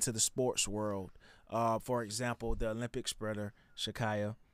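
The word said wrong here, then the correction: Sha'Carri: right? Is that how you pronounce her name? – Shakari. Yeah.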